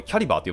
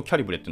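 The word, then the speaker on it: Calibre: キャリバーの、あ、これキャリバーって読むんですね。ずっとキャリブレって読んでたけど、キャリバーって読むらしいですね。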